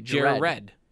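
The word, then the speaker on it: Jarred: It's hard for me to pronounce his name. – Jared. – I want to say Jared. – Should be Jared. – Jared. – No, there's no red. – It's E-D. It's J-A-R-R-E-D. – Jared.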